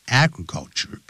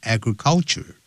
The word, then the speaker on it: agriculture: Agriculture 不要念成 agriculture，它重音在第一个音节 agriculture。